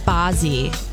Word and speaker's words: Bazzi: I think it's pronounced Bozzy. – There you go. – B-A-Z-Z-I.